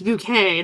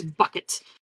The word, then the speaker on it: Bouquet: And she's like, yes, it's pronounced bouquet and not bucket.